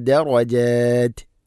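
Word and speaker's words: ദറജാത് (درجات): മിൻകും വല്ലദീന വല്ലദീന ഊതുൽ ഇൽമ വല്ലദീന ഊതുൽ ഇൽമ ദറജാത് ദറ ദറജാത് ദറജാത് റായിനെ കനപ്പിക്കണം, ദറജാത് അല്ല ദറജ ദറജാത്. അതുപോലെ അവിടെ വഖഫ് ചെയ്യുമ്പോൾ രണ്ട് നാല് ആറ് ഹർക്കത്തിൻ്റെ ആ ഒരു ഖദറിലാണ് നീട്ടി നിർത്തേണ്ടത്. നിർത്തുമ്പോഴും താഇന് സുക്കൂൻ നൽകണം, ത ഉച്ചാരണത്തിൽ കൊണ്ടുവരണം അഥവാ അത് പറയണം. ദറജാ അല്ല ദറജത് അത് ദറജത്, ഇങ്ങനെ ഉച്ചാരണത്തിൽ കൊണ്ടുവരണം.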